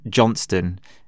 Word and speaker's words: Johnston: When I say to someone, Johnston, like here in Italy, they just have like this look on their face of like, are you from another planet?